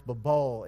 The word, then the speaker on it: Babel: It's the way it's actually pronounced, Babel, is like, it's nonsense. It doesn't make any sense what's being said.